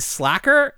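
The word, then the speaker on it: Sla'ker: And we were trying to decipher how to pronounce his name, because it's spelled S-L-A hyphen or apostrophe. S-L-A apostrophe K-E-R. – Yeah. – So Slash... Slacker, so he's Slacker.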